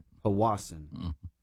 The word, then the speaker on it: Powassan: I looked it up. That's how you pronounce it. – Right. – Powassan.